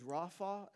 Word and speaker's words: Rafa: And the Hebrew word for be still is Rafa, is how you say it.